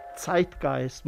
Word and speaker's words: Zeitgeist: Pues hoy viene a iluminarnos, claro, sobre el concepto de, dime si lo pronuncio bien, Zeitgeist. – Zeitgeist, muy bien, sí, muy bien pronunciado.